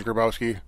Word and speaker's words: Gribowski: My last name is Grabowski. – Mm-hmm. – They spelled my name wrong on my locker my first when I was there. Instead of G-R-I, they spelled it G-R-A.